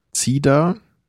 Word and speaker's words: Cider: Cider? – Ja, du darfst aber nicht mit Z aussprechen, da ist ja kein Z vorne dran.